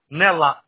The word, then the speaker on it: Nela: And Aldo, your first name is A-L-D-O, and what is your last name? How do you spell that, sir? – Nella, N-E-L-A. – N-E-L-A, N like Nancy, right?